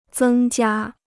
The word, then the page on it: 增加: zēng jiā